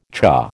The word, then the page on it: cha: American English